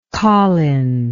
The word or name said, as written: colin